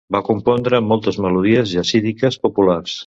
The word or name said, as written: compondre